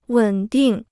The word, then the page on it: 稳定: wěn dìng